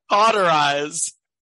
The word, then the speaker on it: cauterize: Well, you gotta quarterize it. Stop the bleeding. Anyway, I'm pretty sure that's not how it's pronounced. – Quarterize, yeah, it is. Yeah. – Cauterize.